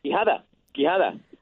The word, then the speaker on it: Quijada: She says I'm hitting the first syllable too hard. Quijada. It's too hard. – Quijada.